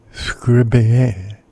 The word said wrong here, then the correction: Scriabin: I can't pronounce that. Scriabin at 7.